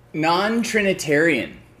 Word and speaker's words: non-Trinitarian: So like this one says denominations as Catholic, Protestant, Eastern, or I don't know how to say this word, non-Trinitarian.